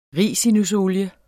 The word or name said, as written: ricinusolie